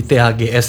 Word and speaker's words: TAGS: Itu bacanya TAGS atau teks sebenarnya? – Better di TAGS sih, Kak. – TAGS. Tapi kalau mau teks juga boleh.